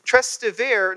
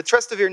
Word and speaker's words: Trastevere: And some think the Trastevere neighborhood, which I pronounced that totally wrong, the Trastevere neighborhood in inner city of Rome.